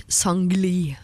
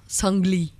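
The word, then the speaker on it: Seungri: Ignite K391 sammen med Alan Walker, Julie Bergan og han jeg nå har lært at heter Sangli. Selv om det skrives Sangri, så uttales altså Sangli.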